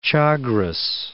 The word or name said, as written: Chagres